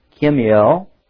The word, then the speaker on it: Kemuel: And then it says that, verse 21, there was also the third child was Kemuel. You pronounce that with K-E-M/U-E-L. U-E-L.